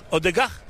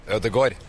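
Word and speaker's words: Ødegaard: What about Odegur? Odegar? – Odegor. – Odegur? – Yeah. – In Brazil, we say Odegar.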